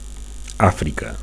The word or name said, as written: Africa